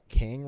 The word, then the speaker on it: Kang: be like, ooh, can you know what ki- Kang rhymes with? Bang.